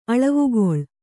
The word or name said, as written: ಅಳವಿಗಳಿ